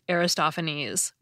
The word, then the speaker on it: Aristophanes: I called him Aristophanes. But it's actually pronounced Aristophanes, which I recognize immediately now that I hear it said the right way.